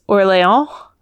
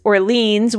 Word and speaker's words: Orléans: Ellen rang in and said, what is Orléans? Which is the French pronunciation. Jen, that was rejected. Jen tried, what is Orleans? Wondering if they just didn't like Ellen's pronunciation of the city. But that's just the... like the anglicized pronunciation.